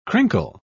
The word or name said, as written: crinkle